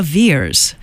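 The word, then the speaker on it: Veirs: So Laura Weir, I do it every single time I want to do the German pronunciation. She's not even German. Laura Veers, The Canyon, from her new album called The Lookout.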